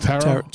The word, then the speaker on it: tarot: Tarot? – Tarot. – Right? – The second T is silent.